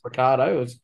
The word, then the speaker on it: Ricciardo: It didn't have so you know how it's spelt Ricciardo, but it's pronounced Ricardo. It was as if someone had just sounded out his name and gone, Ricardo.